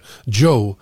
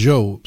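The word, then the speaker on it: Zhou: Så är det någon som hör av sig till mig återkommande att det heter Joe. Man ska uttala det Joe som amerikanska Joe.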